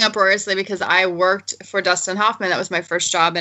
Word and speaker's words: Hoffman: Huffman. – C, Gene Hackman, or D... Robert Redford. – Oh my God! The answer would be a Dustin Hoffman.